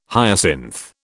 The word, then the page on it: hyacinth: British English